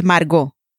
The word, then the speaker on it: Margot: Can you say how it's actually pronounced in Spanish? – Yeah, it's Margot. – You sounded just like the Babbel instructors when you said that. You perfectly rolled the R.